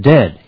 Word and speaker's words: dead: And dead, it's said like bed, not bead. And for goodness sake, don't call it deed.